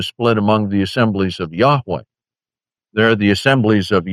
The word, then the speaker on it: Yahweh: Part one, which has to do with the Tetragrammaton, those four little markings that are the Hebrew letters which are pronounced Yahweh.